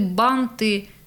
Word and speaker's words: банты: Торт, лифт, бант, где было ударение, во множественном числе там оно и остается. – Угу. – То есть торты, банты... Лифты. Все очень просто.